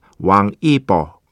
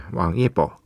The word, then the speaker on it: 왕이보: 네, 오늘 첫 곡 스탠드업 왕이보. 네, 그 한글 그 우리식으로 하는 한자도 왕이보고요. 어, 중국어 발음도 비슷합니다. 왕이보, 예, 왕이보.